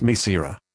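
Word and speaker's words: Mircera: This is the consumer medicine information for Mesira, pronounced Mesira.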